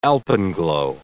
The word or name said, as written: alpenglow